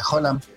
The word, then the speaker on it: Haaland: Que no se pronuncia Haaland, dice que se pronuncia Haaland.